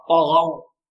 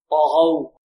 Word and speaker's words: طغوا: وفرعون ذي الأوتاد الذين طغوا، الطاء هنا مفخمة والغين كذلك مفخمة واللام مرقة، طغوا طغوا، لأن حروف الاستيلاء كم حرف قلنا؟